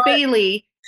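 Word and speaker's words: Baylee: But my name is Bailey, and it's B-A-Y-L-E-E. So it's phonetically correct. So Bay. And then Lee, and then there's a period, and then and co.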